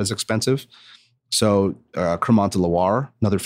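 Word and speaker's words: Crémant: Can you spell that for us? – Yeah. C-R-E- I'm seriously asking. – Cremant. C-R-E-M-A-N-T with an accent over the E.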